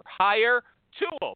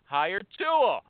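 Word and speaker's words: Hiretual: You think about that for a minute, and I'm going to talk about my new sponsor, Hire Tool. Let me spell it for you. H-I-R-E as in hire, and Tool, T-U-A-L as in mutual. Hire Tool.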